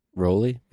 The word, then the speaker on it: Rowley: Rowley is how he says it's supposed to be pronounced, but I don't believe him. I think it's Rally.